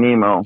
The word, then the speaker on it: Nimmo: Brandon Nimmo.